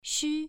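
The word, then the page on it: xu: tone 1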